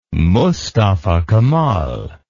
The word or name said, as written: Mustafa Kemal